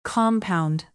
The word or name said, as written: compound